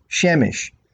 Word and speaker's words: Shemesh: Using an A vowel sound, it is pronounced Shemesh.